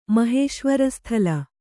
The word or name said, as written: ಮಾಹೇಶ್ವರಸ್ಥಲ